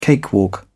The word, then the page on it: cakewalk: British English